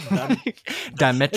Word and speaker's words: Dimetrodon: Dimetrodon. – It's so hard. Dimetrodon.